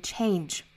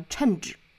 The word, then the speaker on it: change: 我们还需要注意一个重点呢，就是 change 这个单词的发音，大家注意双元音 change, 不要再把它发成了 change。